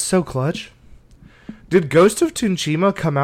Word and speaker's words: Tsushima: Just put the T and S like flow into each other, like Tsushima. – Tsushima.